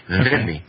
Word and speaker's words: Dhimmi: Dhimmi is just, you're not pronouncing it correct. It's the, like in the word the. Dhimmi.